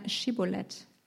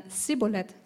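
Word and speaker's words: Schibboleth: Antwortete der Mann, nein, forderten sie ihn auf, sag einmal Schibolet. Wenn er dann Sibolet sagte, weil er das Wort nicht richtig aussprechen konnte, packten sie ihn und brachten ihn an den Furten des Jordan um.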